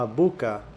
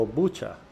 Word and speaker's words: Bucha: Y bueno, eh, el, programa de 60 Minutes había visitado un pueblo que se llama Buca o Bucha, se escribe B-U-C-H-A en Ucrania.